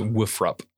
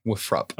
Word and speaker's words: Wofford: It's Wofford is how they say it. – Wofford? – Wofford.